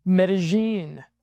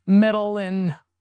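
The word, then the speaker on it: Medellín: This podcast is on the subject of Berlin versus Medellin. That's right. It's not pronounced Medellin or however they said it in Entourage. It's pronounced Medellin or any other traditional gender bastion.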